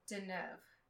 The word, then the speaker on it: Deneuve: Deneuve.